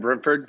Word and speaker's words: Brentford: Brentford. Am I saying it wrong? Brentford? Brentwood? What is it? – No, Brentford? You got it, Brentford.